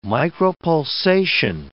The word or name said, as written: micropulsation